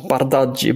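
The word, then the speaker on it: Bardghji: Ronny Badji eller Badji, eller hur uttalar man nu det? – Ja, Bardaggi.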